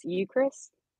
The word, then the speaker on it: Eucharist: I would say, I know I'm going to butcher this, Eucharist? – Yeah!